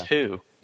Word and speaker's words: Hu: His name is Hu. – Yeah, I got that. – Okay, it's spelled H-U, but no, you pronounce it like who is it? – Right. – Like, knock, knock, who's there?